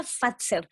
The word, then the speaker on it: Fazer: ¿Cómo se pronuncia? – Se pronuncia Fazer.